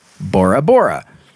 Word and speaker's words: Bora Bora: Because of ambiguities in the phonemes, I don't know what that is, of the Tahitian language, this could also be pronounced Bola Bola or Bora Bora.